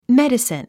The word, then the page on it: medicine: médəsin; メェディスン